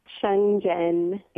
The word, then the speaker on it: Sheng Zhen: And you quote, I guess, some Chinese, Shen Xing. How do you pronounce that? – Sheng Zhen. – Yeah, Zhen teaching. – Sheng Zhen. – Yeah, Sheng Zhen.